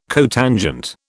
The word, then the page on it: cotangent: British English